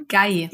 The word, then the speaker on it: Ikigai: Nee, aber ähm, ich werde auf jeden Fall dieses, ich sage es nochmal, Ikikai machen. War das richtig ausgesprochen? Ikigai. – Ikigai.